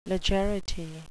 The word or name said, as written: legerity